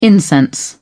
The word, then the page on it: incense: British English